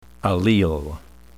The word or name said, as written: allele